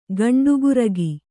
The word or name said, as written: ಗಂಡಗರಿಗೆ